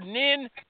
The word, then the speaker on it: Ninh: Ninh is spelled N-I-N-H. How is that properly? How do you say that H for real? – It's a silent H.